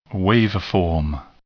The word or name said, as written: waveform